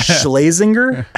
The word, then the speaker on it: Schlesinger: How do you pronounce it? – Schlesinger? – Schlesinger? I've never known how to pronounce his name. – Say the hardest thing about podcasts is definitely names. – Say it one more time. – Schlesinger? – Okay, cool. I like it.